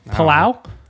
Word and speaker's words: Palau: Let's see if I can pronounce it. Palo. – Oh, I don't know how to pronounce that. I wouldn't have got that. – Palu? Palau. – I'm not sure. It's P-A-O-L-O, right? – P-A-L-A-U. Palau? Palau? – Sure. – Palau. – Let's ride with that.